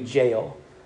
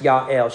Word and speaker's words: Jael: Uh, and that's assuming you don't pronounce the J. If you do pronounce the J, because it's Hebrew, they don't have J's, it would be Jael. Um, but I believe her name is pronounced Yael.